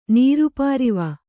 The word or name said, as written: ನೀರುಪಾರಿವ